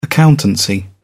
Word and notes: accountancy: British English

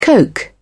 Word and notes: coke: British English

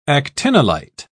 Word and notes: actinolite: British English

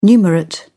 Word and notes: numerate: British English

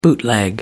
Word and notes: bootleg: American English

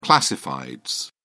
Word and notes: classifieds: British English

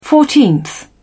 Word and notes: fourteenth: British English

